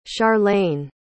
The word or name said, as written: charlayne